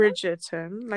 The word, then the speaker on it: Bridgerton: Bridgerton, like a bridge.